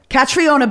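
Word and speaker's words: Caitríona: Catriona Belfrey.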